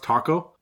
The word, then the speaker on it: tako: It's pronounced taco. T-A-K-O in actual Japanese.